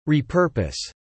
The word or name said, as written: repurpose